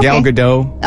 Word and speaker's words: Gal Gadot: Was it Gal Gadot? Did I even say that right? – Yeah. Okay. Gal Gadot.